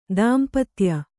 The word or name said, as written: ದಾಂಪತ್ಯ